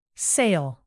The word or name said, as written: sail